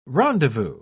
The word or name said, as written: rendezvous